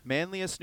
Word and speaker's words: Manlius: But, of course, right now we are here with Penguins forward Anthony Angelo from, correct me on the pronunciation here, Manlius, New York? – Correct. – Manlius, New York.